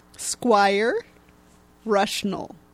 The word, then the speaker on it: Squire Rushnell: Squire Rushnell. – Okay, worth the ask. – Okay, I know I'm pronouncing the first word right, which is squire, but the second word is spelled R-U-S-H-N-E-L-L.